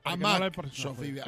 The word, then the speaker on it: Amaxofilia: Amaxofilia. Ahora sí.